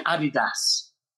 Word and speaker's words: Adidas: And for those in the UK, that's Adidas.